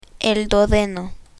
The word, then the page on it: duodeno: Spanish